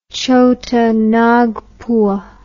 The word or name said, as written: chota nagpur